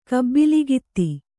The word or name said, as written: ಕಬ್ಬಲಗಿತ್ತಿ